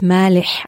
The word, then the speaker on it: Maleh: das war eigentlich, ähm, also ich heiße ja Nadia Malé, mhm. aber Malé ist eigentlich ein syrischer Name. Mein Vater kommt aus Damaskus, aus Syrien und eigentlich wird es Maleh ausgesprochen und Maleh heißt salzig.